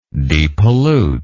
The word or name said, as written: depollution